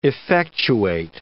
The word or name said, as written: effectuate